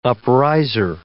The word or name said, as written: upriser